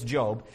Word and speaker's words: Job: It's spelled Job, but it's Job.